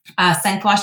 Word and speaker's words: Sang-Froid: I think there's a whole conversation to be had around the name itself, which I'll attempt to pronounce here as Sang-Froid Strategy, which phonetically is not spelt as you think it would be.